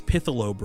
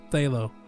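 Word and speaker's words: Phthalo: I think it's Pithalo Brew. Is there like a P in the front of it? – That's what he says. That's how he says it. It's Thalo. – Oh, yeah, the P is silent, like a pterodactyl.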